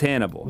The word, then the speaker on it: HNBL: Pronounced as Hannibal. – Yeah, exactly. Yes.